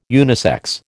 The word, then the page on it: unisex: American English